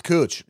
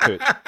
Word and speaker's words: Couch: Spelled couch. – Oh, okay. – Pronounced Cooch. No, that's not us doing it. The whole city pronounces it Cooch. – That's hilarious. – Yeah. Yeah, it's Cooch.